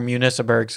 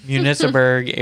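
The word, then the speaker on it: Municiberg: And then bring it back up through the Metropolis area, or Municiburg, sorry, I always say it wrong, Municiburg area, and through those buildings, through One Man's Dream, through all that.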